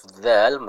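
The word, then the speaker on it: dzal (ذال): Kalau kita membahas huruf dzal ya, huruf dzal, maka huruf dzal ini diucapkan dengan apa? Bertemunya ujung lidah dengan ujung gigi seri bagian atas.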